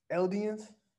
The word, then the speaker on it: Eldians: and then you saw how they they treated the um Eladins or Elidins. How how do you say it? Eldians. Eldians, yeah.